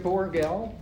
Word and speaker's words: Borgel: Yes, Greg Borgel, 3747 Heritage Avenue, and my name is spelled B-O-R-G-E-L.